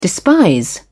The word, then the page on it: despise: British English